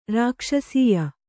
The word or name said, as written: ರಾಕ್ಷಸೀಯ